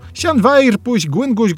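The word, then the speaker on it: Llanfairpwllgwyngyll: Biorąc pod uwagę, że w walijskim podwójne L czyta się jak S, F jak W, W jak U, w oryginale otrzymujemy dość proste pojedyncze słowo. Sianwair puś gwynguś gogery chwyrn drop usian tysilio gogogoch.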